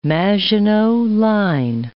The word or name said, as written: Maginot Line